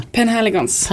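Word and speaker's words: Penhaligon's: Uh, Philanigons, vad heter de? Penhaligons. Penhaligons. Ja, det var nära uttal.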